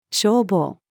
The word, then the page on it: 消防: female